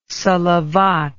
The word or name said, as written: salavat